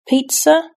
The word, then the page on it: pizza: British English